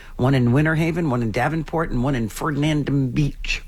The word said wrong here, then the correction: Fernandina Beach: How do you say that? – Fernandina Beach.